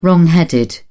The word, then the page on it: wrongheaded: British English